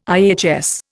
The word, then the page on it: IHS: Indian